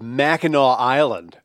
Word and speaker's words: Mackinac: We could talk about the scenic views of Mackinac Island.